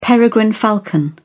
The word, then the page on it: peregrine falcon: British English